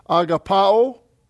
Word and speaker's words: agapao: Since agape menein is a participle, it comes from the verb form, so agapao, and it's a verb, and here's your pronunciation if you don't know Greek. Agapao is what the pronunciation is.